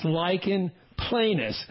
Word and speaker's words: lichen planus: Most medical students don't get it right. It's lichen planus. and it doesn't quite look like what it sounds like, but this is an inflammatory condition.